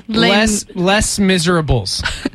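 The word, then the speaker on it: Les Misérables: It's actually pronounced Les Miserables.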